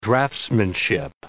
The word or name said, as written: draftsmanship